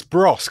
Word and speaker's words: Bros: It's Bros because it rhymes with Goss.